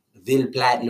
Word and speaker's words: Ville Platte: And so one of the trails that I got to run on at Chico State Park in Ville Platte, Louisiana. – I'm sorry, what was that? – How do you spell that? – V-I-L-L-E-P-L-A-T-T-E. – Okay, so it sounds like it's spelled.